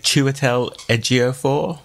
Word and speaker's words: Chiwetel Ejiofor: The movie stars, I'm going to see if I can say this without butchering it, Chiwetel Ejiofor.